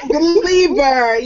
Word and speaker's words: Belieber: Belieber, yes.